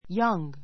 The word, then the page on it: young: jʌ́ŋ; ヤンぐ